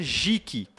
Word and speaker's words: geek: Para quem não sabe, geek, né, se pronuncia geek, que é G-E-E-K.